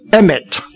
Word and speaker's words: emet: 慈爱就是 hesed，诚实呢就是 emet， hesed emet，就是这个音非常像的，它是一双的东西。这个 emet 也就是我们今天所讲的阿门的来源。